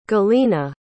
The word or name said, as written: galyna